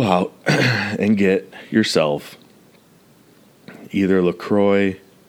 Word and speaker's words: LaCroix: I'd be fucking up the LaCroix, which I also watched a video, and the dude said that the area that it's from, it's actually pronounced La Crosse, but I refuse to say that. I'm just going to call it LaCroix forever.